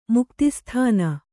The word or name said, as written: ಮುಕ್ತಿಸ್ಥಾನ